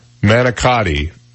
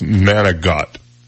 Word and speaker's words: manicotti: Um, manicotti is pronounced manigot. I'm going, no, it's not. It's got a TI on the end of it, man.